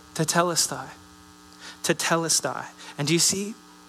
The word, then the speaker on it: tetelestai: The word here in Greek is pronounced tetelestai. Tetelestai.